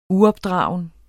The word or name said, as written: uopdragen